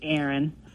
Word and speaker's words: Erin: Oh, my name's Erin. – Erin. – Spell that. – E-R-I-N.